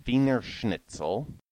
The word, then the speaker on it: Wiener Schnitzel: The word wiener is pronounced with a W sound on its own, but a V sound in Wiener Schnitzel, Edelweiss and Wunderkind have a V sound, Waltz and Wolfram have a W sound.